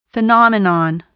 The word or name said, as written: phenomenon